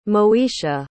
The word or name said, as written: moesha